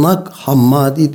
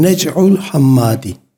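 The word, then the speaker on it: Nag Hammadi: Ya da ne bileyim e, Batılılar kendi telaffuzlarıyla Nak Hammadi diyorlar. Nak Hammadi bölgenin orijinal ismi Nec'ul Hammadi. Fakat bizim dinler tarihi metinlerimize bakıyoruz. Normalde Nec'ul Hammadi demesi lazım. Onlar da Nak Hammadi diyorlar. Çünkü İngilizce öyle. İngilizce telaffuz öyle.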